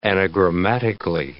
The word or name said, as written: anagrammatically